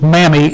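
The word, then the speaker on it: mammy (μάμμη): It's spelled M-A-M-E. It's 3251 in the Greek dictionary, Strong's dictionary. And the English pronunciation is mammy, M-A-M-M-Y.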